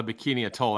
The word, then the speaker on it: Bikini Atoll: Are you saying bikini like a swimsuit bikini? – It's called a bikini atoll. That's how it's pronounced and spelled, but I don't know why it's called that.